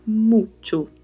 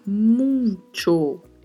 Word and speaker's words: mucho: A pronúncia do CH tem o som no português que normalmente utilizamos ao pronunciar a palavra tchau. Então utilizamos esta sonoridade para pronunciar mucho, mucho. E atenção para mais uma coisa, não falar muito. É muito comum, já ouvi várias vezes pessoas que são falantes do português irem pronunciar mucho e falar muito, muito. Não é muito, não tem uma letra N aí, é muito, muito não tem um N.